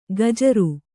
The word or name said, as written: ಖಜ್ಜೂರಿ